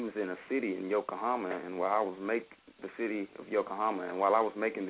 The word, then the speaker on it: Yokohama: Well, I want to play one more song, before we let you go, and it's track number 6, Yokohama.